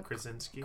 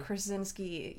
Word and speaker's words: Krasinski: John Krasinski. – Krasinski.